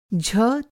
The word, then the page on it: झ: jh